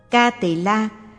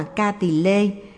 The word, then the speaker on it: Ca Tỳ La: Ca Tỳ La, phiên ngâm từ Phạn ngữ là Kapila, là tên một bộ luận, cũng đọc là Ca Tỳ Lê hay Kiếp Tỳ La.